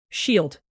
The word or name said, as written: shield